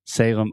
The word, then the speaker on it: Salem: So this is Salem, S A L E M. And remember, whenever you have a word that ends with the letter M in English, we're going to close our